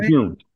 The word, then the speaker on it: Lejeune: Lejeune, yes. Like the month, Lejeune.